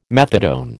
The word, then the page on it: methadone: American English